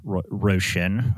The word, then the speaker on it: Roisin: Yeah, R-O-R-O-I-S-I-N, Roisin. I think that's how you pronounce it.